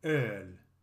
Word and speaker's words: öl: From alu comes the word öl, which means beer, and is pronounced like the word early if you remove the letters R and Y.